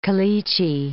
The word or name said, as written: caliche